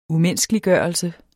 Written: umenneskeliggørelse

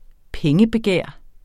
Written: pengebegær

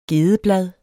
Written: gedeblad

